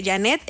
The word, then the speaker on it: Janette: Janet es JANETTE.